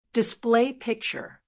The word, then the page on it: display picture: American English